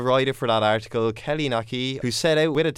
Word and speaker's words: Naqi: Naqi, that's the way of pronouncing. Naqi, it's like hockey except with an N in front.